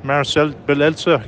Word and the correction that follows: Marcelo Bielsa: I can't pronounce his name. – Marcelo Bielsa.